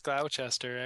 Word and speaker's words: Gloucester: Yeah, it's Gloucester, actually, yeah.